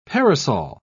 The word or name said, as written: parasol